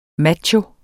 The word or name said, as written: macho